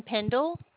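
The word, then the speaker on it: Pendell: Brandon Pendle.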